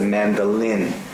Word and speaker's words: mandolin: But this is a mandolin. Make sure the L is pronounced correctly.